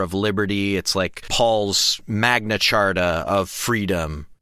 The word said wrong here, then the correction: Magna Carta: Did I say that right? What is it? – Magna Carta. – Magna Carta. – I don't know, thank you. – I don't care. – I knew that didn't feel right.